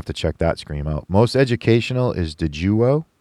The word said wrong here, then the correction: Daewoo: Did I say that right? – Daewoo. That's actually, okay.